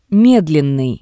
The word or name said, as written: Medlennii